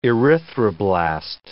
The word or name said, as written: erythroblast